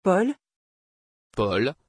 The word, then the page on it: paul: French